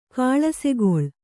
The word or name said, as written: ಕಾಳಸೆಗೊಳ್